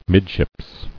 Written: Midships

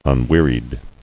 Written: Unwearied

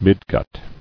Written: Midgut

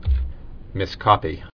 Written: Miscopy

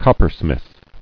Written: Coppersmith

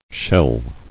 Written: Sheller

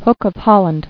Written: Hooking

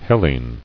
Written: Hellenian

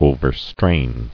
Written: Overstrained

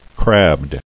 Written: Crabbed

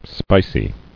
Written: Spiciness

